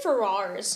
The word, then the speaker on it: Ferrars: I say Ferrars. – Ferrars. Like Ferrari? – Rar. – Rar. Bring us back to 2000s. – Rar. It's like the heart. Rar.